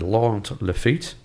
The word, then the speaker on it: Laurent Lafitte: It's a sequel to a 2012 movie called The On the Other Side of the Tracks, and it stars Omar Sy and Laurent Lafitte.